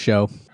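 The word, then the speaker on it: Shough: The quarterback, Tyler Shaw. – It's like spelled like dough, but take the D and put SH. – Let's go with Show.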